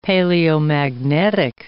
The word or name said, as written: paleomagnetic